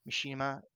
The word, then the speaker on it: Mishima: Did I say that right? Mishima. Yeah.